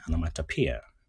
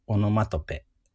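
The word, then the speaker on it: onomatopoeia: アノマトピア。アノマトピアかなちょっと難しかったんだよ、発音が。はい。の、えー、オノマトペともね、カタカナで言うかもしれませんが、まあ、擬態語ですね、を学んでいきましょう。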